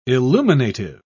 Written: illuminative

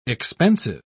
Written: expensive

